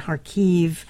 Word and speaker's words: Kharkiv: I wanted to talk to you, about your time in Kharkiv. Did I say that correctly? – Yes. – Okay, phew, okay. It's Kherson. – And Kharkiv. – Kharkiv, okay, right.